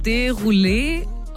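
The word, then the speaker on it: Desrouleaux: Und sein Nachname wird, ich glaube, Derulé ausgesprochen. Derulé? Also geschrieben wird Desroleux. Ja.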